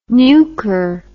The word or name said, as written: nuker